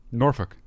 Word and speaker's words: Norfolk: And Norfolk. I think that's how you say it. Norfolk. – Norfolk.